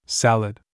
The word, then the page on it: apron: English